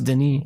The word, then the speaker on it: Denis: No, it is Denis. I think it's pronounced Denis.